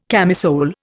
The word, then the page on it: camisole: Indian